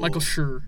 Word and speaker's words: Schur: Michael, is it Schnur? How do you say it? – Michael Schur. – Michael Schur.